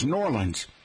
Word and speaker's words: New Orleans: How about new, and I won't say it yet, O-R-L-E-A-N-S, O-R-L-E-A-N-S. New Orleans natives pronounce it as Norlands.